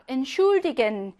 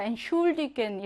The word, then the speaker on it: Entschuldigen: 태 S체하가 하나가 아니라 태 따로, S체하 따로야. 그래서 뭐, 태는요, 그냥 트로 발음하겠죠? 그리고 S체하는요, 슈로 따로 발음하겠지? 이렇게 취가 아니라 트, 슈로 따로 발음합니다. Entschuldigen 이렇게요.